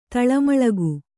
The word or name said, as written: ತಳಾಮಳಕ